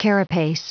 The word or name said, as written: carapace